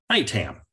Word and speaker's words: ITAM: And am I saying the acronym correctly, ITAM? – ITAM, yeah. That's usually how most folks pronounce it.